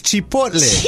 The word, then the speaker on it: Chipotle: It's Chipotle. – Like C-H-E-E-P, like Chipotle. But it's spelled C-H-I.